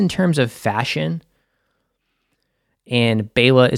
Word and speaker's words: Baela: Last episode, I kept on saying Baela, but it's Baela. I don't know why I kept on saying that. I wanted to hit every syllable.